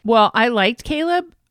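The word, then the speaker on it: Chleb: L-E-B? – Yeah. – Or C-H-A-L-E-B? – No, it's C-H-L-E-B. It's spelled Schleb, but it's Caleb.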